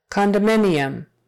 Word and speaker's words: condominium: Now when we say it condo for short, we definitely make that a, a long O, condo, condo. But when we say the whole word, condominium, it kind of becomes an uh sound.